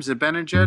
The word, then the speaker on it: Zibanejad: Zibanejad? – Mm-hmm.